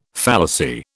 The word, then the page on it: fallacy: British English